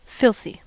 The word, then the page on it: filthy: American English